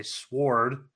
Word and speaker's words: sword: Like, people doesn't say sword. Sword. Right. Sword. How do you say sword? – I guess for me, the W is kind of silent.